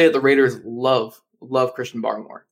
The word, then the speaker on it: Barmore: I think it's Barmore. Is how you say it? – Yeah, I say Barmore.